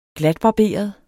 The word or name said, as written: GLATBARBERET